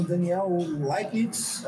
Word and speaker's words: Daniel Leipnitz: E estamos aqui com o Daniel Leipnitz.